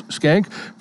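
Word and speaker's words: Schenck: Hi, welcome aboard because I'm talking with Gary and Charles Skank from Skank Insurance and Retirement. How do you spell that? Well, it's S-C-H-E-N-C-K, and they're from Independence, Kansas.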